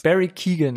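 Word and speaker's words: Barry Keoghan: Barry, ja, das, Barry Keegan hätte ich jetzt gesagt. Ja, klingt, klingt richtig und da er auch aus der Gegend kommt, würde ich das so unterstützen wollen.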